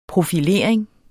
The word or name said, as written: profilering